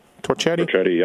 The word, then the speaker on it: Torchetti: John Torchetta. Did I say that right? Torchetti? – Torchetti, yeah.